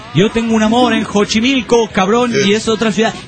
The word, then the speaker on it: Xochimilco: Xochimilco, dice, se pronuncia Xochimilco y no Xochimilco. cabrón, está, son dos lugares distintos.